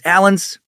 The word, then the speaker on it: Alans: They'd crossed the Volga River in present-day Russia, conquered those Alans. Gotta love that name, by the way. Every video I find says it's pronounced just like the common, you know, just dude name of Alan.